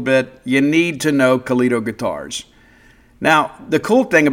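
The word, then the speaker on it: Calido: That's C-A-L-I-D-O. Kalido, right? Rhymes with Lido.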